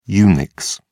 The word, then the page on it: unix: British English